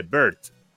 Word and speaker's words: Beard: de Bert. Beard se escribe.